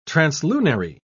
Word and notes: translunary: British English

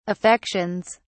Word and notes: affections: British English

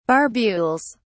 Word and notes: barbules: British English

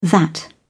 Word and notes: that: British English

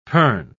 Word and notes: pern: British English

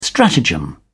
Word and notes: stratagem: British English

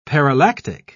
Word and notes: parallactic: British English

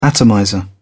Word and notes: atomizer: British English

